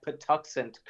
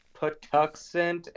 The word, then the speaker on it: Patuxent: I'm also kind of glad that I have been pronouncing that Patuxent correctly. – Oh, yeah. – Like, that is the weirdest. I can't even remember how that's spelled right now, but in my mind, I just sounded out Patuxent.